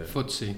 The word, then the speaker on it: FTSE: So, so I can show them on actual numbers that, for example, in financial sector, in UK, when you look at uh, FT, uh, FTSC uh, 500, uh, I'm butchering the FTSE. FTSE, yeah.